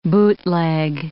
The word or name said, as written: bootleg